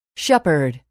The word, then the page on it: shepherd: [ʃépərd]